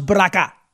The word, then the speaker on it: Baraka: How many of you folks in the black community agree with Raz Baraka? Rhymes with caca.